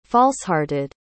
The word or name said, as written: falsehearted